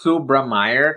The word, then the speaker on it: Subrameyer: you, you, you, you were almost right. So it's Subrameyer. Subrameyer.